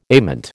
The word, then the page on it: ament: American English